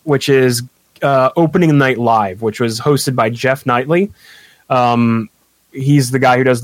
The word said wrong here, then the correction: Keighley: the VGA Awards, the video game Jeff Keighley.